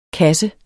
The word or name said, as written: kasse